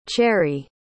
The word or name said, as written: cherree